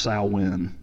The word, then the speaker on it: Samhain: It, it is written as Samhain, but it's actually pronounced Samhain.